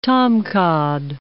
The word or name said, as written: tomcod